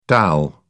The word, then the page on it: dal: British English